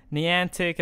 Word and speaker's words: Niantic: Niantic and the Pokemon Company, I mean, they couldn't have asked for a better response with this.